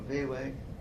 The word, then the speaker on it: Viehweg: Parsons, Admiral Vewig. – How do you spell that name? – V-I-E-H-W-E-G.